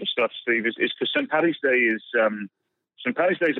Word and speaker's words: St. Paddy's Day: is people calling St. Paddy's Day St. Patty's Day. – Yeah. – It's not the right way to say it.